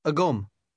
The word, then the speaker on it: agam: Agum. Agum. Agum. – Did you hear the M sound at the end?